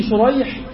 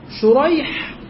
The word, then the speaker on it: شريح: أو الثاني سريج وشريح، شريح روى عن علي، شريح وسريج النطق مختلف، الحروف واحدة.